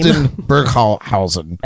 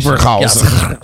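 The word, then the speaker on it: Hildburghausen: Hildenberghausen. – Excellent pronunciation. – Hildenberghausen.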